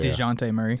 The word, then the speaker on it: Dejounte Murray: Deonta Murray. – DeJounte Murray.